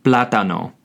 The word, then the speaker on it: plátano: This is spelled E-L space P-L-A-T-A-N-O. El plátano. Keep in mind, there is also an accent over the first A on the word plátano. If there were no accent, it would be pronounced plátano, which is incorrect. Therefore, the accent tells you where to put the inflection on the word. So again, the correct pronunciation is el plátano.